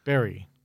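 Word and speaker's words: Bury: Berry.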